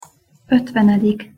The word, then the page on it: otvenedik: Hungarian